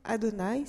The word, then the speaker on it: Adonai: il ne faut pas prononcer ces lettres-là. Il faut prononcer Adonai, c'est-à-dire mon Seigneur.